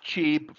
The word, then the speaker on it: Qi: The MagSafe's charging specification, okay, allows for 15 watts of power to be transmitted using the Qi, and that's pronounced Qi from the original Chinese, but you know, written as QI, you know, wireless standard created by the Wireless Power Consortium back in 2008.